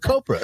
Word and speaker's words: copra: Of copra. Or is it copra? How do you pronounce it? – Is it copra or copra? Copra. – Rhymes with Oprah.